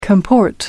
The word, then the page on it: comport: American English